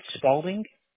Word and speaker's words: Spaulding: It's Spaulding. S-P-A-U-L-D-I-N-G.